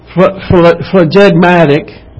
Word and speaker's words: phlegmatic: Now I looked up phlegmatic. How you say it? – Phlegmatic. – Okay. Phlegmatic. And it means to just be laid back, calm, kind of stoic.